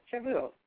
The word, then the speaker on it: Shavuot: Is it? Yeah, Shavuot. Yeah, I said it right.